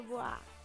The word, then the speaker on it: Bois: Então, né, gente, é Luciana Bois, mas pronuncia Bois, como Courtois, como Stella Artois, como...